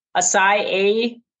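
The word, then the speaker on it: açaí: Acai, A-C-A-I, acai bowl.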